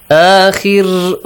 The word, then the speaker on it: آخر: آخر, when you read it, when you stop here, the را is pronounced lightly because yes. There is a كسرة sound.